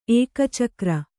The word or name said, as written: ಏಕಚಕ್ರ